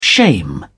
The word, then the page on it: shame: British English